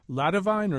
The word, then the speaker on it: Ladivine: Is it La Divine or Ladivine? – It's La Divine.